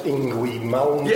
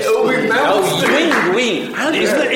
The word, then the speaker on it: Yngwie Malmsteen: My favourite is always the Norwegian guitarist, Yngwie Malmsteen. – Yeah, Yngwie Malmsteen!